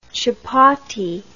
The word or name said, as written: chupatty